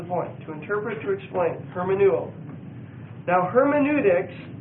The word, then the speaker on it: hermeneuo: The Greek word is hermeneuo, H-E-R-M-E-N-E-U-O. And it's a long E and a long O. Hermeneuo.